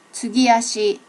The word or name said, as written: tsugi ashi